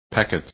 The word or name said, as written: packet